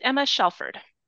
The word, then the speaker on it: Emma Shelford: It's Emma Shelford.